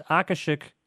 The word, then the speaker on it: Akashic: Akashic.